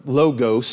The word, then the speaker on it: logos: It's not logos or logos, some people say. Logos is the correct pronunciation.